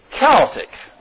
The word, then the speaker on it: Celtic: It's not showing up very good, but it's real fancy letters, and it's a cultic sign here. That doesn't mean occultic. It's just, you know, Celtic. I'm sorry, I said it wrong. Celtic, not cultic. Celtic.